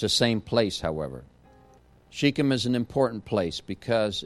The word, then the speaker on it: Shechem: From time to time, you will hear the word Shechem and you'll hear the word Shechem. The actual correct pronunciation is Shechem, but for someone who was raised all their life in Latin culture, or E's or A, so it's Shechem for me, but it's really Shechem for you.